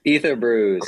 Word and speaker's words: Ether Brews: Ether Brews, cool. like Ethereum, Ether.